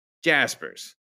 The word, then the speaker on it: Jaspers: Jaspers? Jaspers. Hard J.